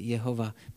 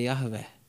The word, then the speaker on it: Jahve: To není jenom, jak říkají jehovisti, Jehova. Mimochodem, správně se to vyslovuje Jahve.